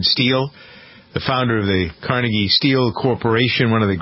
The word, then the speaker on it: Carnegie: Some people pronounce it Carnegie, some people Carnegie. – Carnegie. Carnegie's very fancy.